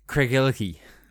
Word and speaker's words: Craigellachie: Craigellachie.